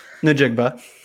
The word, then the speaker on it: Njigba: How do you say Njigba? Njigba? – I say it like the second one you said. – What was the second one? – Njigba.